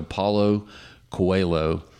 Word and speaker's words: Paulo Coelho: His name was Paulo Coelho. Is the way I believe it's pronounced in Portuguese. So Paulo Coelho.